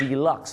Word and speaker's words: deluxe: เขาออกเสียงว่า deluxe นั่นเองครับ deluxe นะฮะไม่ใช่